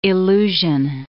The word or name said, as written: illusion